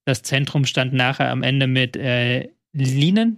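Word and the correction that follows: Lynen: Linen, wie spricht man ihn aus? Lünen. Lünen, Lünen.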